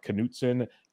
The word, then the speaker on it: Knutson: That'll be coming up here pretty soon, but make sure y'all follow Nikolai on Twitter at Nikolai Knutson, KNUTSON.